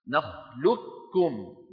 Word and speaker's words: نخلقكم: صفة واحدة من صفات القوة وهي الاستعلاء، لذلك كانت بعض القبائل بل أغلب القبائل كانوا يدغمون القاف في الكاف وينطقون كافا مشددة، فيقولون نخلقكم بكاف إيه،